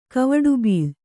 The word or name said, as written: ಕವಡುಬೀಳ್